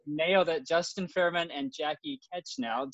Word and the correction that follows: Knechtel: Knechtel. The K is silent.